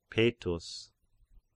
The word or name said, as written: Petus